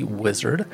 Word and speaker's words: Uizard: That's spelled U-I-Z-R-D, Uizard.